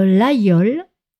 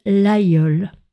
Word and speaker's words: Laguiole: D'ailleurs, en parlant de couteau, ne vous trompez pas pour la prononciation du célèbre laïol qui s'écrit L-A-G-U-I-O-L-E, qui est un couteau très connu fabriqué dans l'Aveyron en France. On prononce le G comme un Y, le laïol.